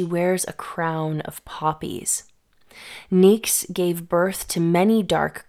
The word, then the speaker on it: Nyx: And sibling to Erebos is Nyx, or Nyx as it's pronounced in Greek, the primordial goddess of night, also one of the very first gods.